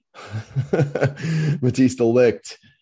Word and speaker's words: Matthijs de Ligt: a couple of the other big moves, Matisse Delight. Uh, I'll pronounce that correctly this time. Matthijs de Ligt.